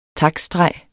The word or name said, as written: taktstreg